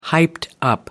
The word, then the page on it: hyped up: American English